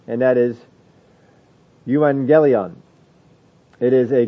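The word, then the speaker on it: Euangelion (εὐαγγέλιον): But you'll get an NG sound when you get two G's put together. Always is that way. Whenever you see two G's, that's how it is pronounced. Euangelion.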